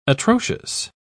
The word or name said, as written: atrocious